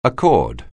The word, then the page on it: accord: anglais